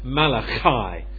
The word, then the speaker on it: Malachi: I guess in the Hebrew they would probably call this guy something like Malachi. Sort of, you know, they'd really pronounce that that CH a bit like loch, as the Scottish people do.